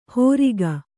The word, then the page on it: ಹೋರಿಕ: hōrika; kannada